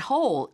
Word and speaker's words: hole: 네, I didn't even notice that hole 이라고 하는데 right. notice도 O 발음이고요. hole 발음도 O가 됩니다. 그냥 notice, hole이 아니라 notice that hole 해서 약간 O 발음에 약간만 좀 신경 써주시면 좋을 것 같아요.